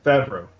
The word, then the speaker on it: Favreau: That's directed by Jon Favreau, or however you say his name. – Oh, yeah, Jon Favreau. – Favreau, thank you.